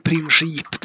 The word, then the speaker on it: Princip: Men vi, Gavrilo Princip blir arresterad i alla fall. Det är det viktiga. Ja, och de andra också. Mm. Eh, och Princip ska du uttalas tydligen. – princip.